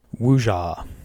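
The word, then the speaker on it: Wuxia: Is it Wuja? Wuju? – Wuja. – Wuja. Wuja role-playing.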